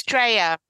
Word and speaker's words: Dreger: It's Dreger, as if the J, you know, is a Y, but it's a common mistake.